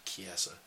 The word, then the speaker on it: Chiesa: I always catch myself and then I hear it later, and I'm like, it's freaking Chiesa.